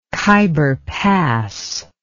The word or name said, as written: Khaibar Pass